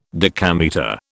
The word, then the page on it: decametre: British English